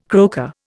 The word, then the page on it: croaker: Indian